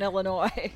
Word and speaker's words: Illinois: There's no pronouncing the S on Illinois.